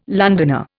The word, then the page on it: Londoner: Indian